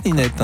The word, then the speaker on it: Linette: L-I-N-E-D-T-E. – L-I-N-E-D-T-E, oui, mais c'est français, Linette.